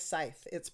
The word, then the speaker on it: Scythe: It is Scythe. I looked it up in the dictionary. It's a long I with S-I.